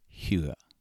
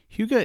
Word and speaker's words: hygge: It is spelled H-Y-G-G-E.